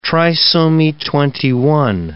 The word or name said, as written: trisomy 21